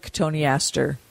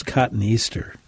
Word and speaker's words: cotoneaster: But yeah, you can do that with cotoneaster, and that should rejuvenate that hedge pretty nicely. – Before I learned the pronunciation from you and your colleagues, I always thought it was cotton Easter.